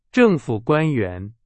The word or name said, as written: 官